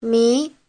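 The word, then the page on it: 迷: mi2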